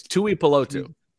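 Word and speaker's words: Tuipulotu: Last name, Marlon Tuiapiolo. I'm sorry. Marlon Tuiapiolo. – You did butcher it. – Yeah, Marlon Tuiapiolo. – Oh, no. It's not Tuiasosopo. They are not related. It's Tuipiolotu.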